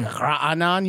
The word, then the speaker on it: Raanan: I'm open because it's a Hebrew name, so it needs... I'm not Israeli, so you need a Israeli accent to say it correctly. Mm. It's like Raanan, you know? – Oh, Raanan. – It has that guttural sound. – Yeah, that. – But in English, you don't have a guttural sound, so...